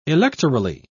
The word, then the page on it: electorally: British English